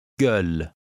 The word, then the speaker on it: Göl: It contains the letter O with two dots over it. This letter is pronounced Ö. Göl.